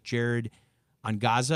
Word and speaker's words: Jared Angaza: Jared Angaza, a little bit better pronunciation that time.